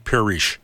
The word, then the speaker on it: Parrish: My name is Tom Parrish. That's the French pronunciation.